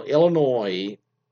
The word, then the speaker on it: Illinois: My husband pronounces the S in Illinois. So it's Illinois. So Illinois, you know, you don't pronounce the S.